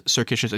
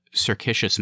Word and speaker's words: circuitous: You are saying it wrong. It's circuitous, and you think of it as a circle.